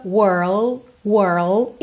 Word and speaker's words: world: Primeiro vai só até o R com a língua enroladinha, were, were. Depois põe o L lá no céu da boca, world, world e fecha com o D, world, world, ok?